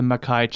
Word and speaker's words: Makkai: She's also taught at the Lake Forest College and held the Mackey Chair in Creative Writing at Beloit College in Wisconsin. So she is a workin'. – It's pronounced the Makkai Chair in Wisconsin.